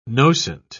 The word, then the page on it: nocent: British English